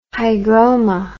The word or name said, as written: hygroma